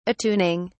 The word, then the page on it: attuning: British English